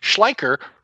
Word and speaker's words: Schleicher: Should old acquaintance be forgot, you should try a mnemonic device, like Schleicher rhymes with biker, and Steven kind of looks like Randall Tex Cobb from Raising Arizona.